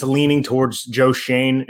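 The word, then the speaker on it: Schoen: And I believe that's the pronunciation, guys. I don't think it's Shone. – Shane, it's not Shone. Okay. – Yeah, I think it's Shane, even though it doesn't look like it's spelt that way.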